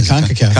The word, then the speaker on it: CONCACAF: Yeah, CONCACAF.